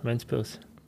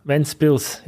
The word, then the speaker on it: Ventspils: Hometown, Ventspils. – Ventspils? – Ventspils, yeah. In Germany it's Windau, yeah, but it's Ventspils.